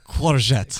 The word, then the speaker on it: Courgette: Courgette. – Courgette. I think that's the French. – It's French for zucchini.